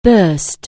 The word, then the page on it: burst: anglais